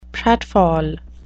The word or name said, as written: pratfall